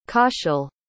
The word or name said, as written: kaushal